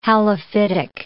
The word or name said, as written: halophytic